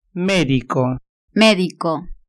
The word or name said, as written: medico